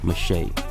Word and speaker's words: Micheaux: Oscar Mache, M-I-C-H-E-A-U-X.